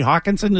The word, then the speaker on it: Hockenson: The kid from Iowa, T.J. Hockenson, is that how you pronounce it? – Yeah.